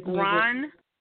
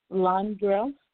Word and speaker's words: Londrelle: And his Ron? – Is more like Londrell. – Spell that? – Londrell. L-O-N-D-R-E-L-L-E.